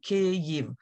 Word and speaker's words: Kyiv: So Kyiv, it's quite easy, Kyiv. So NPR, listen up, get it right.